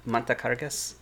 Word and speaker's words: montacargas: Oh, Montacargas in Spanish. Montacargas. Did I say that right? Montacargas? – Si.